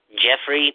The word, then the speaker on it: Geoffrey: Is it pronounced Jeffrey, but it's spelled Geoffrey?